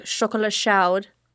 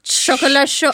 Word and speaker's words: chocolat chaud: I used to work at a chocolate store and we would make chocolat chaud, pronounced correctly. – Chocolat chaud. – Chocolat show.